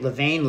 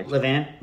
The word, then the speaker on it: levain: Levain, Levan, Le, who? Uh, Levan. That's what I always say. Levan, Levan. Yeah. Uh, Mr. Frenchman, how do you pronounce it? Levan.